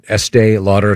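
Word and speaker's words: Estée Lauder: Is it pronounced Estee Lauder? Estee Lauder, yes.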